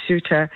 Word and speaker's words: Ceuta: Well, there were people who are migrants who were trying to get to Ceuta, I believe is the pronunciation, this autonomous city of Spain, and it's on the North African coast, so it's bordered by Morocco.